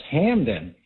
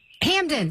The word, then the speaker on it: Hampden: First of all, it's Hampden. – Hampden. Thank you. – H-A-M-P-D-E-N. – I know. Yes, I forget yes. The P is silent.